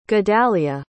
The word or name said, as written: gedalya